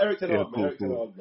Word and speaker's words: Ten Hag: Erik Ten Hag, man.